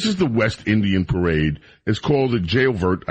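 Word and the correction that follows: J'ouvert: It's a juve. Jailvert. Juve, it's called.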